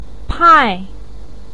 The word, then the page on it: pai4: pinyin